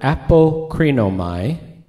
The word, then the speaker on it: apokrinomai: the word that's translated answered is the word apokrinomai. It's spelled A-P-O-K-R-I-N-O-M-A-I, apokrinomai.